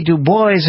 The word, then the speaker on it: Du Bois: One of the messages from the greatest black leaders, beginning with Frederick Douglass and going through W.E.B. Du Bois, and that's how I pronounce his name, and including Booker T.